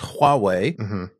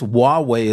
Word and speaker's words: Huawei: But again, I've studied Chinese, and I know that it's pronounced Huawei, which might be a little bit difficult if you haven't studied Chinese and don't have the pronunciation. Ars Technica says Huawei is close enough for Western markets, according to the company.